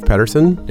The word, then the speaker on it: Petterson: A name like Dave Petterson, though, you have people slip and say Patterson.